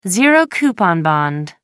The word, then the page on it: zero coupon bond: American English